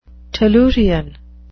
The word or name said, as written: tellurian